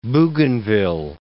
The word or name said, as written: Bougainville